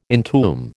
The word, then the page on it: entomb: American English